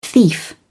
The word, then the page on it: thief: British English